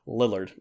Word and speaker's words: Lillard: Lillard? – Lillard.